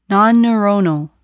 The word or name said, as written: nonneuronal